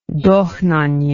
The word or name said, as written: dohnanyi